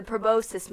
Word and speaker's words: proboscis: But I believe it is pronounced the proboscis monkey.